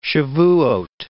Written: Shabuoth

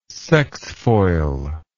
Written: sexfoil

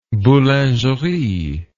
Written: boulangerie

